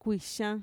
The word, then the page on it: kuixan: Mixtec